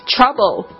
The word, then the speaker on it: trouble: Trouble, T-R-O-U-B-L-E, Trouble, 有时候我们同学就是很搞笑，就会用茶包来去记这个字哦，可是其实这发音有点差别，大家特别注意一下，就是说可以去这样子去记忆，但是发音的时候呢还是要去听正确的发音。 Trouble, Trouble, T-R-O-U-B-L-E, 它也可以当动词，就是不好意思麻烦你，你也可以说 sorry to trouble you, sorry to trouble you, 不好意思麻烦你了。